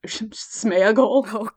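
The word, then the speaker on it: Sméagol: Although that's not how you would have pronounced it. It makes me wonder if that's how he intended it to be pronounced, because in Old English it would be Deagle and Smeagol.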